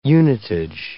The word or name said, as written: unitage